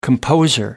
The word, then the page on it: composer: American English